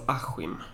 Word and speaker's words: Askim: Och det kommer inte att vara i, det kommer inte att vara i Askim som du ser dem direkt. Det kommer inte att vara i Djursholm. – Det, det uttalas Askim. – Askim, okej, okay, förlåt.